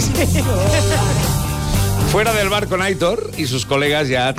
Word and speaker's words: Aitor: que dice, por favor, que Aitor se pronuncia como se lee. – Sí, no. Aitor. – Aitor dice la acentuación es en la O.